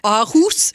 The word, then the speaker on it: Aarhus: Yes, it's not Aarhus or our house, it's Aarhus University.